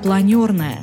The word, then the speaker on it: Планерная: Слово «планер» французское и произносится с буквой «Ё». Первоначально, в момент своего открытия с 1975 и примерно до 90 года, в поездах станция объявлялась как «планерная».